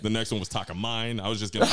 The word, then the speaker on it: Takamine: So I ended up buying just kind of a little Takamine. I never know how to pronounce that correctly. – It's Takamine. – Is it Takamine? – Yep, it is. You had it. You got it.